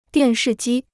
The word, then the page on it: 电视机: diàn shì jī